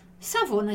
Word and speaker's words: Savona: Savona, già come a gente già pronunciaria no português, né?